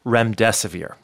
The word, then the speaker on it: remdesivir: Did I pronounce that drug correctly? – Remdesivir, yes.